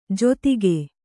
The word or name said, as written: ಜೊತ್ತಿಗೆ